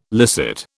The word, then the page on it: licit: British English